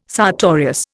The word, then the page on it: sartorius: Indian